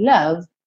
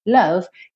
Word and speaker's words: love: Sempre lembrando que love, love, não se pronuncia a letra E. Então, você fala love, love, não, love. Para na letra V.